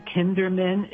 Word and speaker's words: Kinderman: His name is Peter Kinderman. It's spelled exactly like it sounds, K-I-N-D-E-R-M-A-N.